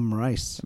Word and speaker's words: Moraes: The Magic Man, Morales. – Oh, Moraes. – Moraes, yeah. – Moraes, okay.